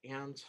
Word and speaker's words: Ann: Ann Thomas 62, I believe, is the Instagram handle with an underscore in between my name.